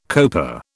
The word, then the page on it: coper: British English